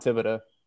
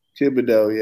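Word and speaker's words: Thibodeaux: Kayvon Thibodeau. – Thibodeau, yeah, that's good.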